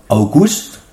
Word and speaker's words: August: Also, den kennen wir als Vorname, dann ist die Betonung auf Au, August, oder August, dann ist es der Monat.